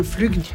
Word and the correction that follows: flic: On ne dit pas flugue, on dit un flic.